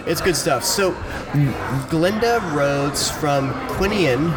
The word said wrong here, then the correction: Quinlan: if that's how you pronounce that. No, sorry, Quinlan. My bad. – Quinlan, yep.